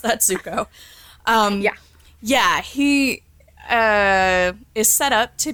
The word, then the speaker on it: Zuko: Something in my gut is like, that Zuko dude, I got his name right? Zuko? – Zuko, yep, you got it.